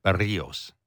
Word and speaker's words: Berríos: I'm trying to say it like Morosi says it. Berríos.